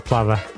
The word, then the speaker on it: plover: Yeah, and then we got told that we were pronouncing it wrong, and it should be plover.